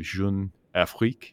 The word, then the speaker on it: Jeune Afrique: Jeune Afrique, if I'm mm-hmm.